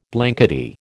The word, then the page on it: blankety: American English